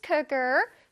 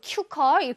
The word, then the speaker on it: cooker: We have a rice cooker to make rice. 자, rice cooker. One more time. Rice cooker. Rice cooker. 자, 큐커. 큐커라고 발음하면 안 돼요? Cooker. Cooker. 아래 턱이 살짝 앞으로 슉 나옵니다.